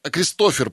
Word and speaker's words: Кристофер: Кристофер, простите, ударение вот так стоит.